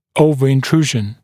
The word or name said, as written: overintrusion